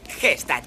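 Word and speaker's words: Gstaad: G-Stad. Quitar la G es cursi. – Has dicho que se pronuncia Stad.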